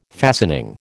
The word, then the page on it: fastening: American English